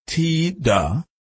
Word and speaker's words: Tida: Kotita, known as Tida, pronounced T-da, was five when her parents divorced.